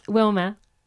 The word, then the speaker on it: Wilma: How would you say it, please? – Wilma. – Wilma? – Yeah.